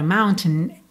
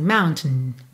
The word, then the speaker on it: mountain: Normalmente, quando você ouvir alguém falando a palavra mountain, essa segunda sílaba fica bem mais pra dentro, assim, mountain, mountain, tá? Muitas vezes do T já vai pro N, mountain.